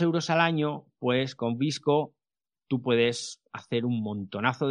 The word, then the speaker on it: VSCO: Algo parecido pasa con Visco, que se escribe VSCO.